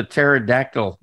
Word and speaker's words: pterodactyl: Why couldn't you hear the pterodactyl going to the bathroom? – I don't know. – Because the P is silent.